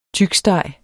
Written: tyksteg

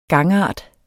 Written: gangart

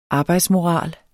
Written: arbejdsmoral

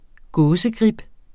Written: gåsegrib

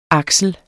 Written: AKSLEN

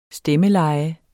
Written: STEMMELEJE

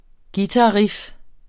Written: guitarriff

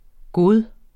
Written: bedste

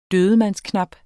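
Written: dødmandsknap

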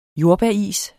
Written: jordbæris

